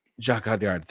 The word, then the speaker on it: Jacques Audiard: James Mangold, for a complete unknown, the Bob Dylan biopic, and Jacques Audiard for Emilia Perez. – Jacques Audiard. – Thank you. Sorry, my French is terrible.